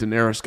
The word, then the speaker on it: Daenerys: I heard that people are very upset that Daenerys got killed.